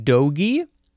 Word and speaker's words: dogie: The next word is, I think you pronounce this one dogie. Dogie. D-O-G-I-E, noun from 1888.